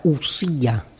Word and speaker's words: ousia: Sustancia es una traducción latina de la palabra usía, se escribe ou, ousia, que en griego significa algo así como la identidad de algo.